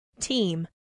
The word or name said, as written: team